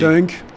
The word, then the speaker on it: Tunk: Alors vous venez représenter Tunk, je ne sais pas comment prononcer. – Tunk. Tunk. Oui, Tunk, oui, oui, c'est un terme Wolof.